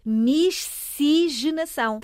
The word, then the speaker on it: miscigenação: Então, em bom rigor, de acordo com a escrita, a pronúncia é miscigenação. Tal e qual como se escreve.